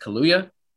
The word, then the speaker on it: Kaluuya: Daniel, so you pronounce it Kaluuya, will also be in that as well.